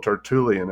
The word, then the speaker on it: Tertullian: So you quote Tertullian.